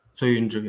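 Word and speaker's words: Söyüncü: And the left-sided center back, I believe it's pronounced Soyuncu, is that correct? – Soyuncu, yes.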